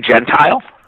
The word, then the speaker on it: Gentile: We say Gentile. – Gentile. – I think it's Gentile, yes. It's ironic that on the Goldberg show we have a guy with the last name of Gentile.